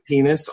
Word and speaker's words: PEN15: And the other thing that I started watching, which I know I'm late on, it's spelled PEN15, but it's penis on Hulu.